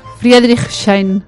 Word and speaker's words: Friedrichshain: Friedrichshain.